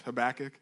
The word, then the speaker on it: Habakkuk: You can pronounce it a couple different ways, all right? Habakkuk, I'm going to choose Habakkuk. It's got 3 K's in it, just in case anybody's wondering.